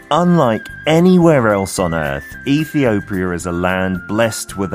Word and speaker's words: Ethiopia: 아, Ethiopia we say in English. Ethiopia. Mm-hmm. I got it.